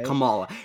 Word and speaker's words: Kamala: Yeah, Kamala.